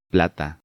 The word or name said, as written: Plata